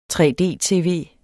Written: 3D-tv

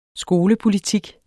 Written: skolepolitik

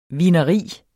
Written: vineri

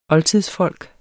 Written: oldtidsfolk

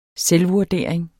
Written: selvvurdering

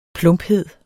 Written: plumphed

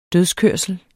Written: dødskørsel

